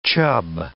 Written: chub

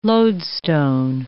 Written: lodestone

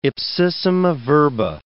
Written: ipsissima verba

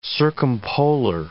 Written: circumpolar

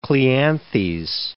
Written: Cleanthes

